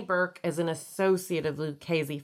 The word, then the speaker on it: Lucchese: Lucchese. – Lucchese? Lucchese. – Oh, it's CC. I knew that. – Double C is a hard C. – Double C is a K.